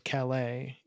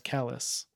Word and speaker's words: Calais: Unless you're in Maine, in which case Calais, which should be pronounced Calais, in Maine is pronounced Calais.